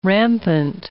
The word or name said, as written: rampant